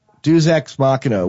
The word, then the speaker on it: Deus Ex Machina: it says Deuces Machina on it. How do you say that? Deus Ex Machina, which is Latin for the god in the basket, which is basically a cheat in the hero's journey.